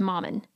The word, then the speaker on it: mammon: I wrote down how to say it. It's mammon.